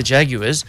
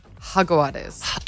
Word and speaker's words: Jaguares: They say the J is pronounced with an H. – Jaguares. – That's correct.